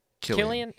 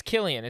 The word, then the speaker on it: Cillian: Starring Cillian, that's how you say his name, right? – Killian. – It's Killian. It's not with a C.